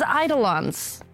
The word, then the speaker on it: eidolon: What are these eidolons? Am I saying this correctly? – Yeah, that's an eidolon. Yeah, that sounds right.